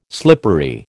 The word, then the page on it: slippery: American English